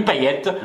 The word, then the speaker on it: Payet: Alors, ça se prononce comme dans le sud, ça se prononce Pougnasse. En fait, non, non, t'es c'est Emmanuel Pougnasse. Non, non, c'est à La Réunion d'où je viens, ça se dit Payette. Donc effectivement, ceux qui disent Payette, ils ont raison.